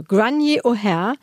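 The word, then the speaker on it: Gráinne O'Hare: Und es ist eins meiner Lieblingsbücher der letzten Wochen von Grainne O'Hare und übrigens eine irische Autorin und diese Aussprache, das schreibt sich etwas anders, habe ich direkt bei der Übersetzerin Annalena Kroll erfragt. Die hat mir eine Sprachnachricht bei Instagram geschickt. Also Grainne O'Hare, belegte Aussprache der Autorin,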